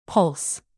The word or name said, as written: pulse